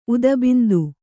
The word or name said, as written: ಉದಬಿಂದು